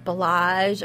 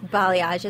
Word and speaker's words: balayage: So right now, what's really popular is balayage. – Which people mispronounce all the time, right? – Yeah, they do. We get a lot of calls for balage or belong. It's pronounced balayage.